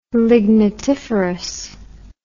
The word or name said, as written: lignitiferous